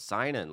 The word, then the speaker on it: Sinan: I'll go, it's sign in, like sign in your name.